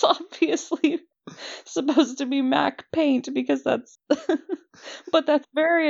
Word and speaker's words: MacPaint: I always pronounced it McPaint, and I was berated multiple times in my childhood by Mac users who were like, no, it's Mac Paint.